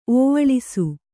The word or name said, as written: ಓವಳಿಸು